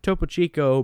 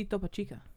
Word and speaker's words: Topo Chico: The trouble began just before midnight Wednesday at the Topo Chico prison when a struggle... – But, you pronounce that incorrectly. – No, it says Topo Chico. – Maybe Topo Chica. – No, it's Chico.